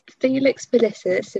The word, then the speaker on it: Felix Felicis: Felix Felicis, if I'm saying it right.